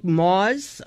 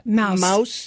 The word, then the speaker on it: Maus: There's a great example is this book Maws, I think it's pronounced, M-A-U-S. – Yeah, the comic, well, comic book, the illustrated Mouse, the Holocaust book.